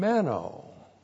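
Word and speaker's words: Meno (μένω): Special with John, Meno. M-E-N, long O.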